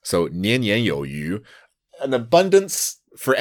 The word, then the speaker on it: 年年有余: Yeah, it's a slang called 年年有余. – 年年有余. – Yeah. So the 余 here means sufficient, but it has the same pronunciation as fish.